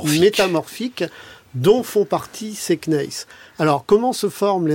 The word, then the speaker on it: gneiss: Vous dites dans le livre que toutes ces roches qui ont des noms magnifiques, comme je l'ai dit, se sont finalement transformées en gneiss. Est-ce que j'ai bien prononcé? – Tout à fait.